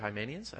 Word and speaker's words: Hymenaeus: So I went into Pastor Lance's office and I said, how do you pronounce this? And he said, Hymenaeus.